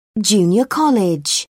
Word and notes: junior college: British English

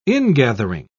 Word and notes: ingathering: British English

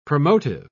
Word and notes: promotive: British English

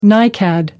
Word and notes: nicad: American English

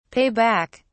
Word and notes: pay back: British English

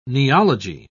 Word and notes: neology: British English

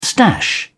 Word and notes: stash: British English

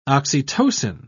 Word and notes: oxytocin: British English

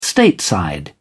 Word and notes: stateside: British English

